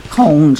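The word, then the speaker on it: Koehn: Cone should really be pronounced K-O-H-N. K-O-E-H-N.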